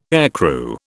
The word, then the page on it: aircrew: British English